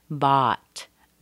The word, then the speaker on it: bought: Remember the B-O-U-G-H, it's not all, it's ah, In most regions of North America, it's pronounced "I" as in father, "bot," "bot."